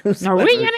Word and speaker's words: Norwegian: Norwegian. Norwegian es que se pronuncia.